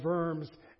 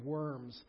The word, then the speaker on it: Worms: A diet was just a council, and worms, German, V, W is a V, is pronounced as a V. So it is the diet or the council of worms.